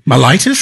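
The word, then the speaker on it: myelitis: It's transverse mitis. – Mylitis? – Transverse mylitis, yes.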